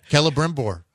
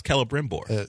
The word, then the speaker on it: Celebrimbor: Celebrimbor. I know that. – There, how could you not know Isildur and be able to pronounce Celebrimbor? – The eye threw me off.